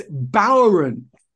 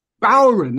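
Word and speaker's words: bodhrán: But anyway, getting back to Paul Horahan, he pointed out to me that I mispronounced the name of Sheamus's 10 beats of the, and it's Bowron. Bowron, that's how you pronounce it, I believe.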